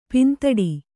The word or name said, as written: ಪಿಂದೊಡೆ